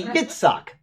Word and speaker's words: Yitzhak: So when you see the name I, you don't say, who's somebody that we pronounce wrong like that? – Isaac. – Yeah, it wouldn't be. It would be Yitzhak.